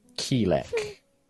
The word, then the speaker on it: Keelek: You are a cleric of Hesper, are you not, Quilek? – Keelek. – Ah, the spelling in the dossier gives a otherwise different idea. – I'm aware, Mark! Finally an RP way to tell you that you've been saying it wrong this entire time.